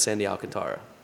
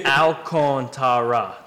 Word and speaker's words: Alcantara: Okay, so when you look at Baseball Reference, it says all in all lowercase, then capital letters for K-O-N, and then Tara, I believe, which to me, that's Sandy Alcantara.